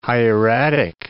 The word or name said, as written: hieratic